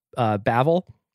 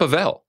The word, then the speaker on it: Bavel: Kaya just informed me I've been pronouncing it wrong, but a lovely restaurant downtown called Bavel. – Oh, it's not Bavel. – I thought it was Bavel. Kaya informed me otherwise.